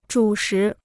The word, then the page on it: 主食: zhǔ shí